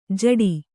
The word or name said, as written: ಝಟಿ